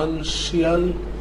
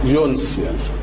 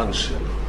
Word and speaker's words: أنشيال: أنشيال ساعات يعني لو عاوز تنطقها ساعات بتبقى أنشيال أو يونسيال كده أو كده، بس غالبا بينطوها أنشيال.